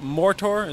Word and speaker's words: Mothra: Who collides with Mortar, is that how I'm pronouncing it right? – Yeah.